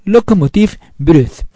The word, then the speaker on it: Locomotive Breath: Perdón, Locomotive Breath. Pronunciémoslo bien.